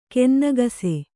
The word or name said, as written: ಕೆನ್ನಗಸೆ